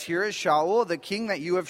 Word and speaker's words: Shaul: Saul's name in Hebrew is pronounced Shaul. Shaul comes from the Hebrew word sha'a, which means to ask.